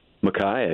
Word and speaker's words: Micaiah: How do you say that? – Micaiah, yeah.